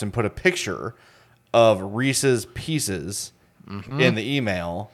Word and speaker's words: Reese's Pieces: Oh, I say Reese's Pieces. – That's the correct way to say it.